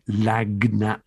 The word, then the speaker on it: lagniappe: Lagniap is spelled LAGNIAPPE, lagniap, but pronounced lagniap.